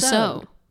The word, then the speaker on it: Tussaud: I think it's Marie Tucson. Tussaud. – Yeah. yeah. Maybe I don't pronounce the D.